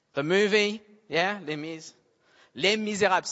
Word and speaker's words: Les Misérables: Because I only say it the French way, Les Misérables.